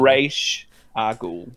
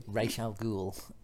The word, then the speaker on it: Ra's al Ghul: And I'm going to pronounce it correctly so you know what it's going to be. – I already know. Go on then. – Ra's al Ghul. – Ra's al Ghul. – Pronounced correctly.